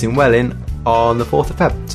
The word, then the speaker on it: Welwyn: Welwyn. – Welwyn. – Yeah. – All right, okay. Is this spelled W-E-L-W-Y-N? – Yes. Welwyn, it's Welwyn Garden City.